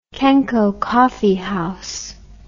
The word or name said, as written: Kenco Coffee House